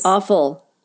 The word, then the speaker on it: offal: O-F-F-A-L. Let's look it up. – Oh, God. – Okay, Shelley. – Yes? – Awful. Here, let's see if we have a pronunciation of it. – Awful. – That's funny. – Awful. – Awful. – The way she says it is awful.